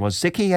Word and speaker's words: Wojcicki: So there was an interview recently with the CEO of YouTube, Susan, I can't pronounce her name, Susan Wojcicki, I think it's pronounced.